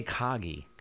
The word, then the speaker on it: Kagi: How do we want to pronounce this, just so we get on the same page? – I'm going to say Kagi.